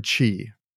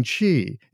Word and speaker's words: qi: It works by using qi, in parentheses, spelled, you know, pronounced C-H-E-E as in cheese, blah, blah, blah, blah, blah.